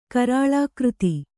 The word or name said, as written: ಕರಾಳಾಕೃತಿ